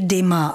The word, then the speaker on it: Dumas: Píše se Dumas a měli bychom se tedy držet té výslovnosti Dumas, ale to bychom skloňovali podle vzoru předseda.